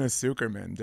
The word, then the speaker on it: Tsukerman: That looks like Irene Tsukerman.